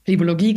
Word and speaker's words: Phlebologie: Und habe ich Phlebologie richtig ausgesprochen? – Plebologie klingt genau wie es sein sollte.